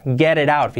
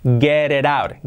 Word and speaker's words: get it out: Get it out, fijaos. Get it out. Get it out. Get it out. Bien pronunciado. Get it out. Get it. Get it out. La R. La T, como R suave.